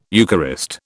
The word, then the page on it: Eucharist: British English